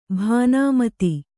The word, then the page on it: ಭಾನುಮತಿ: bhānumati